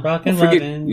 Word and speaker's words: Rockin' Robin: This is Rockin', like Rockin'. Like I'm Rockin'. – Rockin'? – Yeah, like the song, Rockin' Robin.